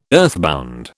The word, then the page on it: earthbound: British English